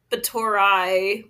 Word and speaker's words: Báthory: So her name, it's spelled Báthory. – Okay. – But phonetically, I guess it's pronounced basically Báthory, like with an I at the end.